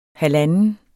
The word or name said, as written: halvanden